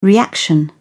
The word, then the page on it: reaction: British English